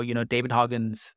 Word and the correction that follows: Goggins: I think I got the name right. David Hoggins, yeah. But he wrote this great book. Goggins.